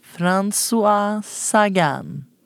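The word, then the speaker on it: Françoise Sagan: Och den är skriven av François Sagan.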